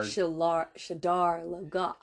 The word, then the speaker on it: Shadar Logoth: We left off with episode 2, where they were all now out of Shadar Logoth, and they are... – Shadar Logoth. – Yeah, that's how they say it in the show.